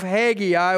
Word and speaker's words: Haggai: And we see this with the people of Judah in the time of Haggai. And so I invite you to turn your Bibles to the short book of Haggai, or you can pronounce it Haggai.